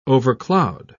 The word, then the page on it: overcloud: British English